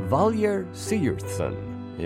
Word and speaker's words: Sigurðsson: Valur Sigurðsson is his name. Lots of G's there, which are apparently pronounced as Y's.